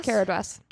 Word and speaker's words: Caradhras: They decide to go through the pass of, how do you say it, Caradhras? – Caradhras.